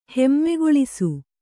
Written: ಹೆಮ್ಮೆಗೊಳಿಸು

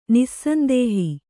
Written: ನಿಸ್ಸಂದೇಹ

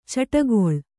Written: ಚಿಟ್ಟುಕೋಳಿ

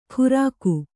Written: ಕುರಕು

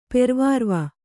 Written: ಪೆರ್ವಾರ್ವ

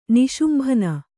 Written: ನಿಶುಂಭನ

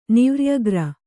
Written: ನಿವ್ರ್ಯಗ್ರ

